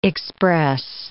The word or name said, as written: express